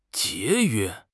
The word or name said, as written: jie2 yue1